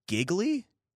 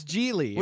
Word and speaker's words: Gigli: Well, it's spelled G-I-G-L-I. Giggly. Is it not Giggly? – Giggly. – Geely? – No, it's Geely.